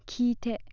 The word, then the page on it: kiite: Japanese